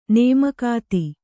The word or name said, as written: ನೇಮಕಾತಿ